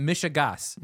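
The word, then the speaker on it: mishegas: We're surrounded by Mishagash. How do you say that? Mishagas. – Mishagas. Yep.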